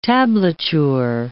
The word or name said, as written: tablature